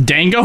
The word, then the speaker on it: dango: Wait, is it not pronounced dango? Were we pronouncing it's dango? – Dango, yeah.